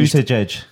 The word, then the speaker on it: Buttigieg: I thought particularly Kamala Harris and Pete Buttigieg, I believe his name is pronounced. – Really Buttigieg.